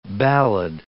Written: ballad

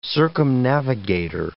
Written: circumnavigator

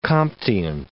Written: Comtian

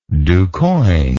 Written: duquoin

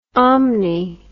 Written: omni

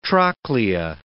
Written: trochlea

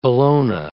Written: Bellona

